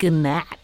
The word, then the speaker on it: gnat: I love you, gnat. – Nah, the G is silent.